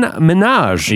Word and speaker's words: Minhaj: Also coming up later on the show, we talked to Daily Show senior correspondent Hasan Minhaj. – Yeah. – He's coming up later. It's pronounced like Nicki Minaj.